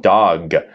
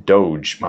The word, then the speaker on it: Doge: 然后这个 Dogecoin 就是大家看到那个狗头形象的在互联网上传播的那张动图，就叫 Doge 啊，长得像 dog，但是呢。啊，不是念成 dog，而是 Doge 啊，Dogecoin。